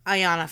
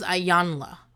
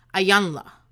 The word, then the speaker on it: Iyanla: I'm, I'm never saying her name right. I think her name is Iyanla. Iyanla. But I think we've all gotten away with saying Ayana but that's not her name.